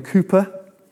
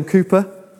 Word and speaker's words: Cowper: So this is a guy called William Cooper, which is spelt Cowper, and that kind of annoys me, but there we go. William Cooper.